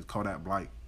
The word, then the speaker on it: Kodak Black: And that's how them people Florida say his name, Kodak Blight.